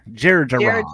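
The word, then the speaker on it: Jerjerrod: Is it Terjon? or Jerjon? – Jerjarod. – Jerjarod. Okay, yeah.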